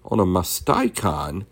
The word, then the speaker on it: Onomasticon: In the Onomaticon, let me see if I'm pronouncing that right, Onomasticon, Eusebius locates Gilgal at about the second milestone from Jericho.